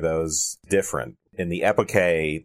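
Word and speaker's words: epoché: So let's talk about the bracketing, the epike. Is that how it's pronounced? That's how it's pronounced in the ancient Greek.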